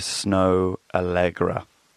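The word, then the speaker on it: Snoh Aalegra: It's called Find Someone Like You, and the artist is Snow Allegra. It's spelled Snohaallegra.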